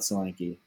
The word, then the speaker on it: Solanke: Solanke.